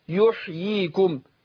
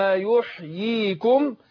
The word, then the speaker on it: يحييكم: ناخد بالنا من اليائين هنا هنا اسمه تمكين أقول يحيي ما قولش يحييكم كده على طول أقول يحييكم يبقى اليائين هنا ناخد بالنا في كيفية النطق أقول إيه ثم يحييكم